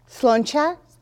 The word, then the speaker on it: Sláinte: Sláinte.